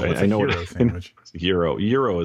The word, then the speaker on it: gyro: I mean, like you don't go around saying gyro, right? – No. – Okay. – Wait, how do you say it? Is it euro? I say euro. – Euro, yeah. That's how I believe that's how the Greeks pronounce it. Before any Greek listeners call me out, and I believe it's euro. – Yeah, but not hero.